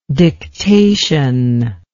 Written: dictational

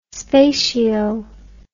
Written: spatio

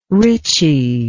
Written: richie

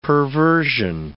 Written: perversion

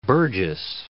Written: burgess

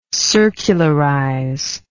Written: circularizer

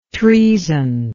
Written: troezen

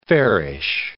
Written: fairish

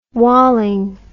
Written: walling

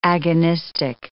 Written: agonistic

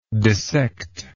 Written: dissectible

